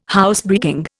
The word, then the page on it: Housebreaking: Indian